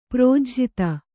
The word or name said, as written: ಪ್ರೋಜ್ಝಿತ